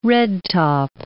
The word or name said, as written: redtop